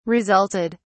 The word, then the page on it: resulted: British English